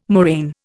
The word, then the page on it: moraine: Indian